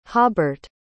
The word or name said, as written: hobert